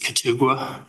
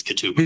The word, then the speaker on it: Katugwa: Sergeant Jason, and I believe the pronunciation is Katugwa, and it's spelled Quit Ugwa, but I believe it's Katugwa.